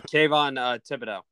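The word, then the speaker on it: Kayvon Thibodeaux: Oh, Kayvon Thibodeau.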